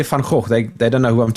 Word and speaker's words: Van Gogh: The actual pronunciation of Vincent's surname is Van Gogh, but most people won't be able to pronounce that. Yeah, that's actually how it's pronounced in Dutch, Van Gogh.